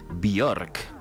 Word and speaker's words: Björk: Bueno, acá estamos escuchando a Björk. – Björk.